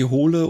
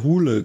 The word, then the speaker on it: Hole: den ich mit seiner Harry Hole oder Hule, glaube ich, wird es ja ausgesprochen, Reihe total mag.